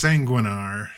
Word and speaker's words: Sanguinar: Last our latest single player content featuring Valeera Sangarar uh, is available for free uh, free to all players. I don't know how you pronounce that. It's like sanguin, It's sanguinar. – Sanguinar, yeah, like blood.